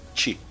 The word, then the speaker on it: Qi: Escreve-se Qi e pronuncia-se Ti.